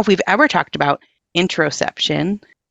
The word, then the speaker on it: interoception: You talk about, and I might say it wrong, Dr. Aliza, interoception? – Interoception, yeah. – And I totally said it wrong.